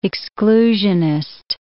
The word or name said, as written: exclusionist